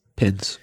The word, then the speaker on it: Pince: Pince.